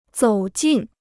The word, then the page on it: 走进: zǒu jìn